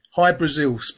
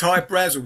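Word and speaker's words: Hy-Brasil: There's High Brazil, High Brazil. People pronounce it High Brazil, and it's Brazil. Okay. – Yeah. But it's High Brazel because that's how the Irish would pronounce it, because it's an island that's off the southwest coast of Ireland.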